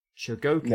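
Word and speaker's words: Chogokin: For example, I used to have Soul of Jokogin Soul of Jokogin. Mm-hmm. That's how you pronounce it. Soul of Jokogin.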